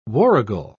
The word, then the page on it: warrigal: British English